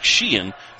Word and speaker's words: Sheehan: That is a little different pronunciation for me. It looks like Sheehan. It's Sheehan. I'm sorry.